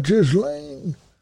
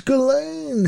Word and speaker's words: Ghislaine: My God, it's not Ghislaine. It's Ghislaine. Half the name is silent.